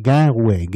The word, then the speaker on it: Guenrouet: Das ist äh, Guerre, Guerre, äh, nee, Entschuldigung, ich spreche es falsch aus, Garouet. Garouet ist französisch ausgesprochen, aber auf Bretonisch heißt es äh, Gwenret. Äh, und deshalb habe ich das ein bisschen jetzt halb bretonisch ausgesprochen.